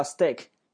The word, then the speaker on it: steak: OK，所以那个牛排啊，回过头来啊，牛排那个叫 steak，一定要读成 D 的发音，不能读成 steak 啊。